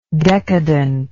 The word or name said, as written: decadently